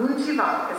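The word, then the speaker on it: Muon Thi Van: Wishes is a book that tells, the author's name is Moon Thi Van. Moon Thi Van is how you say her name.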